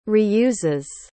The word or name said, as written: reuses